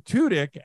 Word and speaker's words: Tudyk: We'll explain all of it as we go, but I have seen your name pronounced 100 different ways, right? Yeah. And every interview, it's Tudyk, Tudyk, but it's actually Alan Tudyk, and you actually put it phonetically on your Zoom.